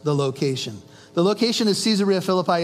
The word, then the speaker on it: Caesarea Philippi: Now, when Jesus came into the district of Caesarea Philippi, you could pronounce this Caesarea Philippi because it was named after the Caesar, the Roman emperor.